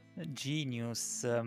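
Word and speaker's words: jeen-yuhs: E invece poi Gin Yus, come si pronuncia Genius? – Genius, mamma mia, certo.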